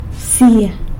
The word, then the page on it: bsia: sanlucasquiavini